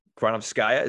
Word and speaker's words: Granovskaya: I mean, Marina Granovskaya, is that how you pronounce it? – Yeah.